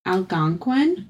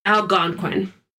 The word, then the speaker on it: Algonquin: Is it the Algonquin, or is it... – Algonquin. What did I say? Algonquin? I'm so sorry. Algonquin.